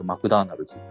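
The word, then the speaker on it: McDonald's: この 2 人がマクドナルド、向こうの発音だとマクダーナルズですね。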